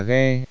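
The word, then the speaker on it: ghe (歌): Từ ca trong tiếng Trung có âm đọc là ghe, vèo.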